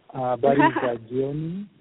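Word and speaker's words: Habari ya jioni: Yes, it's nighttime there, so I should say Habari, how do you say, Habari Yajioni? – Yes, Habari Yajioni, Habari Zawusiku.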